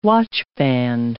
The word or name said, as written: watchband